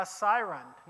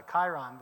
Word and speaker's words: acyron: Now notice the spelling, A-C-Y-R-O-N. Using Merriam-Webster online this week, I discovered, to my chagrin, I have been mispronouncing it for 30 years. So I always thought it was a chyron, but it's a siron, according to Merriam-Webster.